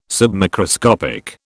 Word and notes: submicroscopic: British English

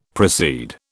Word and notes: proceed: British English

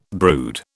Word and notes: brood: British English

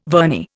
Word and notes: vernier: Indian

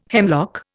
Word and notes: hemlock: Indian

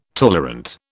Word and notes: tolerant: British English

